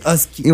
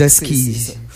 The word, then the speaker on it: Huskies: Ça s'écrit Huskies, mais ça se dit Huskies. – Ouais, ça se dit Huskies. C'est un S silencieux.